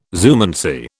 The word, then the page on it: zoomancy: British English